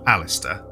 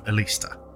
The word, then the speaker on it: Aleister: Edward Alexander Crowley, known as Aleister, or Alistair, depending on how you pronounce it. I prefer Aleister.